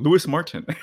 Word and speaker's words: Luis Martin: Or you can also do the anglified version, Louis Martin.